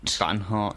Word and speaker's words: Banhart: Devendra Banhart? Barnhart? Am I saying it right? I don't know. – I think Barhart? – Banhart. – Banhart, yeah. With his cover version of Kath Bloom's Forget About Him.